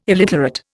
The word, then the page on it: illiterate: Indian